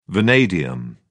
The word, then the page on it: vanadium: British English